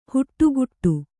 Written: ಹಿಟ್ಟುಗುಟ್ಟು

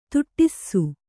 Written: ತುಟ್ಟಿsಸು

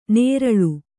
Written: ನೇರಳು

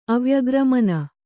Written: ಅವ್ಯಗ್ರಮನ

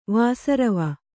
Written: ವಾಸರವ

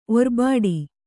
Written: ಒರ್ಬಾಡಿ

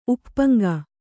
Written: ಉಪ್ಪ್ಪಂಗ

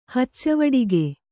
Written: ಹಚ್ಚವಡಿಗೆ

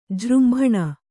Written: ಜೃಂಭಣ

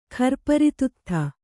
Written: ಖರ್ಪರಿತುತ್ಥ

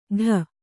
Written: ಠೂ

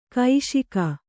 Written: ಕೌಶಿಕಿ

